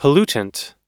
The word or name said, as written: pollutant